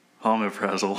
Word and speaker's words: Omeprazole: Omeprazole.